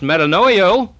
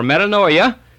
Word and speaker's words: metanoia: And it is the Greek word which is pronounced metanoio, or metanoia, and it always means change your mind.